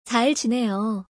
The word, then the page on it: 잘 지내요: Korean